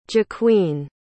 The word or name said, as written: jaqueen